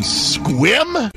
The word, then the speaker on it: Sequim: You know what's happening in Squim? It's spelled Sequim, but it's pronounced Squim.